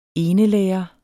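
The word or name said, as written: enelærere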